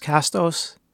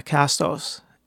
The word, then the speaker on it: Castos: I think it's pronounced Castos. Yeah, Castos.